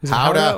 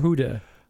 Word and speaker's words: howdah: Howdah or huda? – Howdah. Say it right, Frenchie.